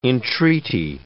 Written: entreaty

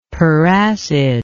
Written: peracid

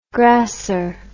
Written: grasser

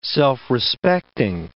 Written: self-respecting

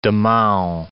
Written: Damao

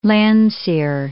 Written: Landseer

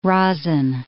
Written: rosin

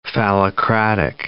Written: phallocratic

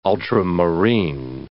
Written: ultramarine

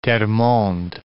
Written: Termonde

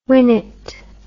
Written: winnet

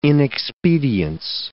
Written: inexpedience

